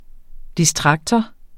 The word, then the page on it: distraktor: [ disˈtʁɑgtʌ ]